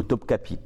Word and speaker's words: Topkapi: J'ai eu l'occasion de visiter le musée de, le palais de, je me trompe de le mot, Topkapi.